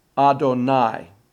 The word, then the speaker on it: Adonai: Notice the pronunciation in the last line. Adonai. A-I. Titaru. H-A. Adonai titaru.